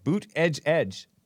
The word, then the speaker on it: Buttigieg: I don't know how he pronounces the name, but that's how Trump pronounces it. And that's how he's said to pronounce it. Buttigieg.